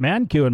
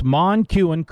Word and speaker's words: Monquin: I pronounced it Monquin, my fault. It's Monquin Creek Outfitters and Monquin Creek Land Management in Virginia.